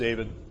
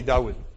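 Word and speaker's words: Dawud (דוד): In Hebrew, it would actually be, it's not really pronounced David, probably Dawud. Is how they would pronounce it.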